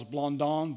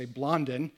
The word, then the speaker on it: Blondin: In the 1800s, there was a Frenchman by the name of, I think it's pronounced Charles Blondin, but I'm American, so I'll say Blondin.